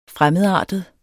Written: fremmedartet

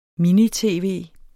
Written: mini-tv

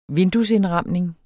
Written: vinduesindramning

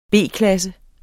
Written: B-klasse

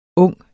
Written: ung

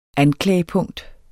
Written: anklagepunkt

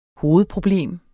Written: hovedproblem

